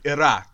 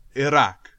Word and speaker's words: Iraq: I tried to say it with the official pronunciation, Iraq. – No, please say it again. – Iraq.